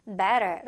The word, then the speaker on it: better: better 발음 두 개 있는데 여기서는 R 발음으로 T가 약화가 되면서 better 라고 들렸습니다.